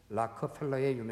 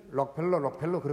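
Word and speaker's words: Rockefeller: 여러분, 우리 세계 역사상 최고의 부자로 꼽히는 락커펠러의 유명한 일화가 있죠. 여러분, 우리는 록펠러 그렇게 얘기를 하는데 그건 뭐 우리 일본을 통해서 소개되다 보니까 일본식 발음이라 그런 거고 락커펠러라고 이렇게 얘기하잖아요.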